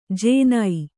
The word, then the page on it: ಜೇನೈ: Kannada